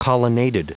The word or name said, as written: colonnaded